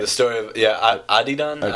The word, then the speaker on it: The Story of Adidon: Pusha came up with the story of Adidon. Is that how you say it? – The story of Adidon.